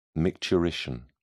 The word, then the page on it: micturition: British English